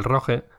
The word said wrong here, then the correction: rogue: rogue sería, ¿no? La pronunciación correcta.